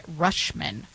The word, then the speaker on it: Rutschman: Rutschman.